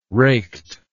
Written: raked

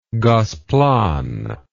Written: Gosplan

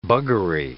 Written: buggery

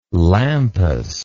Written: lampas

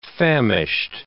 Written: famished

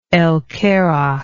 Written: El Kerak

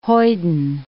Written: hoyden

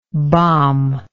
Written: bombable

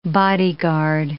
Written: bodyguard

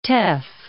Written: teff

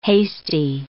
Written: hasty

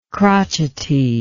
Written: crotchety

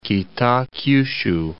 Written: Kitakyushu

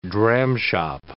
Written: dramshop

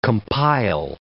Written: compile

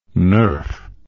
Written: nerf

